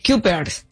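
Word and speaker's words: Cuypers: C-U-Y. – Kuipers.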